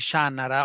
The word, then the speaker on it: Shannara: Con un altro linguaggio, Paola, è stato quello della serie di Shannara. L'ho sentito pronunciare così da Terry Brooks.